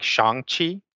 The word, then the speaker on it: Shang-Chi: So, according to, I haven't seen it, but according to the trailers, it's pronounced like Shang-Chi, right? – Right.